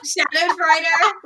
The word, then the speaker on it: Schadenfreude: Well, how do you pronounce it? Schrodenfreuder? – Schadenfreude. – Schadenfreude.